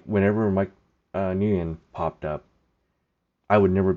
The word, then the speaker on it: Nguyen: So Beardsley and Dennis both knew a Vietnamese guy named Mike Nguyen. That's how they pronounce it. My ex, her last name is also Nguyen, or in Vietnamese pronunciation is Nguyen.